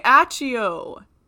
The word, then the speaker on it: Accio: No, it's literally Accio in Latin. Oh, my gosh. C is pronounced like a K, but double C before E-I-Y-A-E and O-E is pronounced T-C-H.